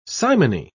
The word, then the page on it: simony: British English